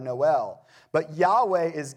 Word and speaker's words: Yahweh: And it may have been pronounced something like Yahweh.